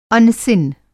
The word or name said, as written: अनसीन